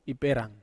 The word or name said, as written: peran